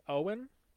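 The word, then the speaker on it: Ywain: Oh, yeah, it's Y-W-A-I-N. So it's like Yewin or Ewin. I'm going to say Owen, I think, is a good is an acceptable pronunciation.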